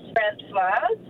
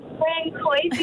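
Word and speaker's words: Françoise: My real name is actually Francoise, but it's spelled Francoise. So every time I go to the doctor's or a medical centre, they come out and they're like, is Francoise here? – Oh.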